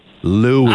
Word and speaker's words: Louis: Is the baby's name pronounced Louis or Louis? – Louis.